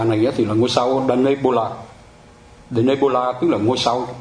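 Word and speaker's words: Denebola: Deneb Al-Asad thì họ đọc là Danebola.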